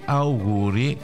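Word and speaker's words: àuguri: Gli auguri erano gli auguri con l'accento sulla A,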